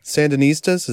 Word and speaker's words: Sandinistas: Sandinistas, is that how you say it? – Uh-huh.